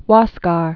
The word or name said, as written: Huascar